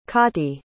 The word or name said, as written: cotty